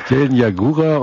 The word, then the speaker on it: Jelenia Góra: Du warst ja im Gerhard Hauptmann Haus tätig in Jelenia Góra, wenn ich das richtig, wahrscheinlich falsch ausspreche, wie ich es Jelenia Góra? – Jelenia Góra, und dieses Gerhard Hauptmann Haus hat sich ja zur Aufgabe gemacht, schon auch immer wieder Veranstaltungen zu Gerhard Hauptmann anzubieten.